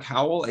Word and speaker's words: Howell: My name is Mark Howell, H-O-W-E-L-L.